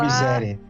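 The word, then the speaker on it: Mysaria: A prostituta que o Daemon é a Missária, eu não sei como se fala. Ela... – Miséria. – A Miséria, exatamente. É exatamente isso.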